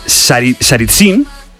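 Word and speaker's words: Tsaritsyn: Saritsyn...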